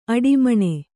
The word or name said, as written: ಅಡಿಮಣೆ